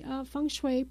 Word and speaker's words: feng shui: Okay, feng shui, pronounced feng shui, was developed over 4,000 years ago in China.